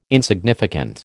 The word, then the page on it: insignificant: American English